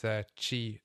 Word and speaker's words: Qi: I guess it depends how expensive this Qi stuff is.